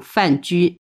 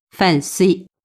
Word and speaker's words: 范雎: 这个里面被我抓到了一个一个比较重要的字，这里面有个重要的角色叫丞相范雎，读成了范 C。